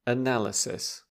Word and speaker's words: analysis: And it's hard because the noun and the verb are very different. The sound is very different. – To analyse an analysis. – Yeah, stress changes, doesn't it? Analyse stresses on the first syllable. Analysis stresses on the second syllable.